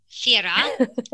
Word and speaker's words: Fira: But yeah, and even in Santorini, we did a fantastic authentic meal just there in Fia, was it? I'm going to say it wrong. Hold on. Fira. Sorry, they pronounce things differently.